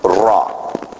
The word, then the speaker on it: ra: So instead of just saying evil, let's everybody just say the Hebrew word ra. Ra. Ra. Can y'all roll your R?